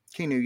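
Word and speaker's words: Keanu: would you say his name was Kenu? – Kenu, yeah.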